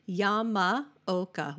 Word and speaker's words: Yamaoka: So Yamaoka, Y-A-M-A-O-K-A. It's sort of like Oklahoma.